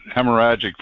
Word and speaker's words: hemorrhagic: And they're going to come up with something new, which could be this hemorrhagic, or how do you pronounce that? – The what? – The fever. Hemorrhagic.